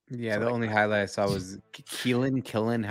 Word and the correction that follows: Quinnen: You mean Quinnen? Quinnen Williams? – Quinnen. I don't know how to say his name. – Really not hard. Quinnen.